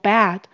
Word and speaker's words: bad: 这边加 s 也是因为前面的主词是第三人称单数，空气闻起来很臭、很糟糕、很不舒服，我们可以用 smell bad 来表达。请留意 bad 是念成 bad，这边的 a 是蝴蝶音哦，嘴巴要撑大一点，不然很容易会念成像床，床是 bed bad bad 很糟糕 bad bad。